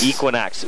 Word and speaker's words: Equinox: How do you say it? – The Equinox. – Equinox. – Yes. – I just ask you because we had some sort of a disagreement at the station today because there's one of our employees wants to call it Equinox. – No. No, that is incorrect.